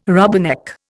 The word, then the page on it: rubberneck: Indian